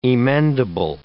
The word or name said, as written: emendable